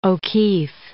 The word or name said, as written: O'Keeffe